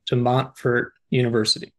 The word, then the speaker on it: De Montfort University: De Montfort University. And I might have, as I said, butchered the pronunciation of that. – That's perfect.